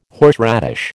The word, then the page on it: horseradish: American English